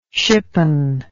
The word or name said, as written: shippon